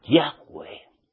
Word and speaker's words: Yahweh: No iba Yahshua diciendo YHWH. No iba diciendo Yurgel, Valgel. No. Sino que Yahweh lo pronunciaba.